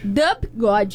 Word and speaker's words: Dub God: Dub God. Se escreve assim, D-U-B-G-O-D na camisa dele, tá escrito assim.